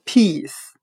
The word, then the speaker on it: piece: 那我们用谐音法来记忆这个单词 piece，它的发音很像汉语的劈丝。